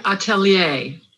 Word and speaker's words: atelier: Talk to us about your atelier. How do you say it again? – Website atelier. – Atelier.